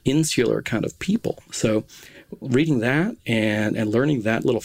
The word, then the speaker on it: Reading: officially, I think, pronounced Reading Writing and Arithmetic, because Reading is their hometown. They're from Reading, Berkshire, England.